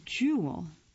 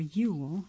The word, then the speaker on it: Juul: Under the heading Business, how Juul, or Juul, it's spelled J-U-U-L, got vaporized.